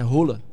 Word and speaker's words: Haaland: Sabia como é que é a pronúncia do nome dele? – Sim. – É Rola. – Rola? – É. – Rola de rola? – Isso é rola. Ah, quem falou fosse no norueguês, então eu não vou discutir com eles. – Qual foi a pronúncia do nome? Rola.